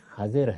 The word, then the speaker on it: خضر: عربی زبان میں اس کا تلفظ بھی خضر ہے، لیکن ہماری اردو میں اب یہ خضر بولا جاتا ہے، اور اس کو ہمارے شاعروں نے باندھ بھی دیا ہے، اس لیے اس کو اختیار کیا جا سکتا ہے، اس میں معنی نہیں، متنبع رہنا چاہیے کہ اصل تلفظ یہی ہے خضر۔